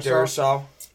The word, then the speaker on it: Darrisaw: I had them going with a lineman, Christian Darrisaw. I think that's how you pronounce it. Yep, you went Darrisaw.